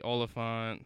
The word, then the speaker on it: Olyphant: Anya Taylor-Joy, De Niro, Rami Malek, Mike Myers, Zoe Saldana, Timothy Oliphant.